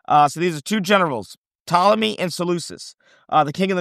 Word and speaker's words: Ptolemy: P-T-O-L-E-M-Y, Ptolemy. It's a silent P, okay?